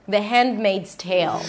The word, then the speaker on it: Handmaid's Tale: The Handmaid's Tale.